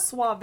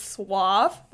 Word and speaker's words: suave: And when Emma goes, it's not suave, it's suave, that was mm-hmm.